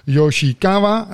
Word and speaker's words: Yoshikawa: Uh, I'm not pronouncing it very well, but uh, I, I, think no, it is good. – It is good. Oh, thank you. that worries me. She's global brand ambassador of Chichibu, and Chichibu is probably the Japanese hottest brand out there in the world at the moment. Hi, Yumi. – Thank you very much. Hi, Jan, and hi, Denise. I'm Yumi Yoshikawa your pronunciation is actually good. Yoshikawa is perfect.